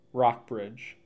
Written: Rockbridge

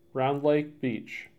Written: Round Lake Beach

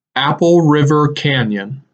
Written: Apple River Canyon

